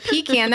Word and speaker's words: pecan: Well, because you're saying it wrong. That's half the reason it doesn't sound good to you. If you called it a pecan, that sounds much better.